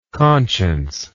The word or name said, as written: consciencelessness